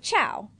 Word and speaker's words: ciao: It's spelled C-I-A-O. – Yeah, like chow.